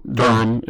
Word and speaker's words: Durham: And if you are from Durham, then you would know Durham is the correct way to pronounce it, not Durham.